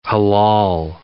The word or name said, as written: halal